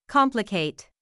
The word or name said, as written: complicate